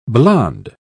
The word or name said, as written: blond